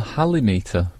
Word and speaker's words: halimeter: Would you pronounce it the halimeter? – Oh, I'm sorry. – I would have gone for the halimeter. Because of halitosis.